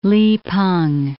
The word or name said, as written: Li Peng